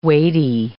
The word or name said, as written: weighty